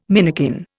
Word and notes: minikin: Indian

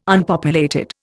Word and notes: Unpopulated: Indian

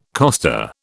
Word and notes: coster: British English

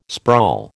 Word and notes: sprawl: American English

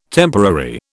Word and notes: temporary: British English

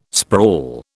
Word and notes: sprawl: British English

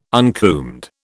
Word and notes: uncombed: British English

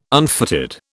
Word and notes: unfitted: British English

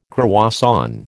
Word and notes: croissant: American English

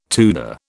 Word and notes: Tudor: British English

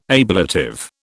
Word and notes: ablative: British English

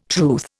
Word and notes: truth: Indian

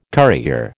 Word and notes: currier: American English

